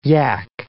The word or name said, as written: yak